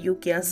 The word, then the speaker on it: Ukiah: He arrived in Ukiah and guys, I'm sorry if I'm butchering that name, but I looked it up and everybody pronounced it Yukia, so blame them, not me.